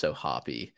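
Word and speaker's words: O'Hoppe: I'm almost certain it's Ohapi. – It is, so you need to pronounce the E, okay? I wasn't sure if the E was silent.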